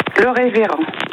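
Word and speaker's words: Lerévérend: Le Révérend. – Le Révérend, donc L-E-R-E-V-E-R-E-N-D, hein, le Révérend, comme ça se prononce. – En un seul mot? – En un seul mot. – Oui. – E-N-D à la fin.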